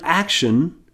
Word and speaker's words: action: The base in the word action, well, the ion is pronounced un in action. Un. The base act is pronounced aksh in action. The t is writing the sh.